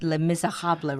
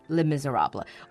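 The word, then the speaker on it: Les Misérables: The French pronounce it Les Miserables, right? Like with the R sound. But English speakers would just say Les Miserables or Les Mis.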